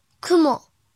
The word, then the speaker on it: 雲: 安温安温安温，训读的时候读作“ クモクモクモ”。